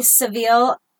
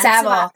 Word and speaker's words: Savile: 2012 Jimmy Savile allegations. Again, Savile.